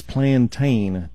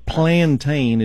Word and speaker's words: plantain: Almost like the word plantation, but it's plantain, T-A-I-N. – P-A. – No, plantain is P-L, I'm going to spell it, here we go. – Okay, plantain is spelled P-L-A-N, and then T-A-I-N, plantain, and then golden seal.